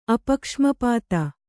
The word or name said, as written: ಅಪಕ್ಷ್ಮಪಾತ